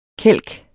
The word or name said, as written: kælke